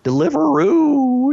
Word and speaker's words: Deliveroo: Deliveroo. It sounds like a kangaroo, but with deliver in front of it.